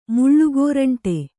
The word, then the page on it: ಮುಳ್ಳುಗೋರಂಟಿ: muḷḷu gōraṇṭi